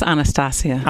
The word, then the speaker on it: Anastacia: And is it Anastasia or Anastasia? – It's Anastasia.